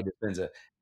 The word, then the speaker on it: Dispenza: And you touch on some very interesting literature and just sources of inspiration for you, like Dr. Joe Dispenza, I think that's how you pronounce it. Is that how you say that? Dispenza. Okay, Dispenza.